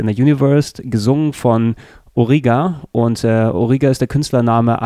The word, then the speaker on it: Origa: Und wer jetzt ganz genau aufgepasst hat, dem ist aufgefallen, dass Origa eigentlich nichts anderes als die japanische Aussprache von Olga ist.